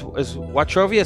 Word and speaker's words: Wachovia: Is Wachovia supposed to sound like that? – Yeah. – Like we watch over the money? – Anchovies.